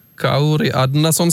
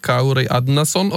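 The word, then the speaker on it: Kári Árnason: Kauri Adnason skal altså det uttales, hvis vi skal begynne å uttale alt rett. da. Mm. Kauri Adnason.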